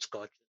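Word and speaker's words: Koch: I think it's pronounced Scotch. – I I think it is. I think you have it right.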